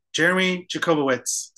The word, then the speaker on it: Jeremy Jacobowitz: Jeremy Jacobowitz.